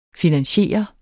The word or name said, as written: finansiere